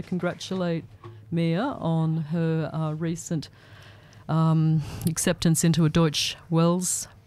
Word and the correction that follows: Deutsche Welle: program, or Deutsche Welle. I think that's the right way to say it, isn't it, Mia? – Deutsche Welle.